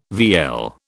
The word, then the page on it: vielle: British English